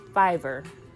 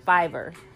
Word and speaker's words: Fiverr: It's called Fiverr. Yes, I said it right. Fiverr.